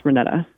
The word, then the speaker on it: Renetta: It's Renetta. – Great. – It's like Renee with an extra couple letters.